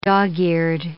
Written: dog-eared

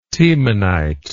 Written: tiemannite

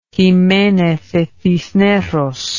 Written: Jimenez de Cisneros

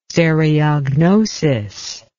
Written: stereognosis

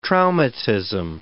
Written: traumatism

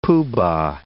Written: pooh-bah

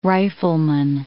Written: rifleman

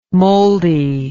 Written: mouldy

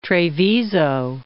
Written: Treviso

